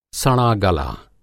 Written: सड़ागला

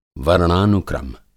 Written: वर्णानुक्रम

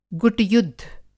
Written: गुट-युद्ध

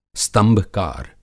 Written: स्तंभकार

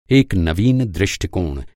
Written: नवीन

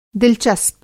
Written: दिल-आरा

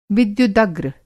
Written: विद्युदग्र

